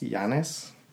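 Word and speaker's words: Janus: So Janis, I don't know if a lot of people are familiar with him. I've also heard it pronounced Yanis.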